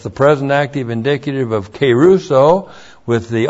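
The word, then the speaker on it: kerusso: And then keruso. K long E R U S S long O.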